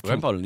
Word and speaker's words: Rempeln: Am I saying that right? Rempeln? – Rempeln, yeah.